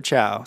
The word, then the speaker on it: Chou: Is it Cho or Chow? C-H-O-U.